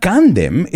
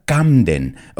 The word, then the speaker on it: Camden: Oh, and there's another mistake I often hear with the British pronunciation, and it's here in Spain, a lot of my students say that they love Candem. Candem. But it's not Candem, it's Camden. La M viene antes que la N.